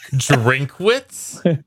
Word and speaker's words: Drinkwitz: Drinkwitz? – Nah, it's Dinkowitz. – It's two syllables, and the first one of them is what I do with beer, especially when I'm watching Wisconsin.